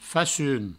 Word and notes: facum: Provençal; facun

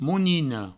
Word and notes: monina: mounino

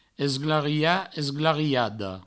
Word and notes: esglariat, esglariada: provençal; esglaria; esglariado

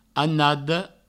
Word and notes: anada: annado

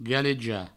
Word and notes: galejar: galeja

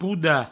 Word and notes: podar: pouda

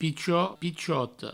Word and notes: pichot, pichota: pichot, pichoto

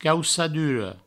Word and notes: caucadura: provençal; caussaduro

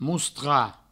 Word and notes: mostrar: moustra

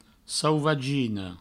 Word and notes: sauvagina: souvagino

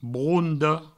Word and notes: bronda: broundo

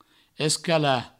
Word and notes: escalar: escala; provençal

